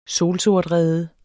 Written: solsortrede

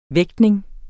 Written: vægtning

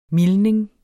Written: mildning

